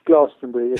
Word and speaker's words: Glastonbury: Yeah, I went to Glastonbury, which is in Somerset, and I should say it's pronounced Glastonbury if you live in Somerset. All right, don't be modern and the BBC and call it Glastonbury, because it's not called Glastonbury.